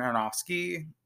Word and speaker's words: Aronofsky: Tuesday, Paramount Plus is releasing a documentary series by Darren Aronofsky. That's how you say his name, who did Black Swan and The Fighter.